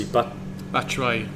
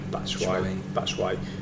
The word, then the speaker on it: Batshuayi: Chelsea, Batshuayi. – Batshuayi.